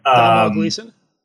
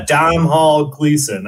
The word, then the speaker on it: Domhnall Gleeson: But Domhnall Gleeson? – Yeah, Domhnall Gleeson.